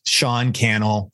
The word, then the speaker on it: Sean Cannell: Yeah, if people want to connect, I'm at Sean Cannell, rhymes with YouTube channel on all the social medias.